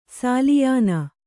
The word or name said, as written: ಸಾಲಿಯಾನ